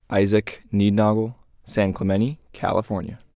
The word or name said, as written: Isaac Niednagel